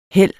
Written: hæld

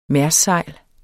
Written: mærssejl

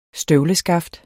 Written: støvleskaft

